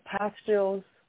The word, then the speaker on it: pastilles: They come in a little gel pastilles, that they pronounce that as French, that you can dissolve on your tongue.